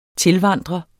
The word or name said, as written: tilvandre